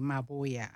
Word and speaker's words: Mabuewa: It's a silent B, Mabuewa.